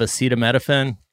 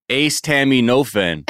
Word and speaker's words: acetaminophen: Wait, that's how you say it? What is it? – Acetaminophen? – I love Kirkland brand. – I thought it was Ace Tammy Nofen.